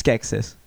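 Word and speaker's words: Skeksis: I really it rhymes with Smexis. It's Skexis.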